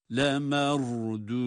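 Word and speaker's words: لَمَرْدُودُونَ: Le merdudune fil hafirah. Le merdudune. – Ra kalın. Dal harfleri ince. – Le merdudune fil hafirah.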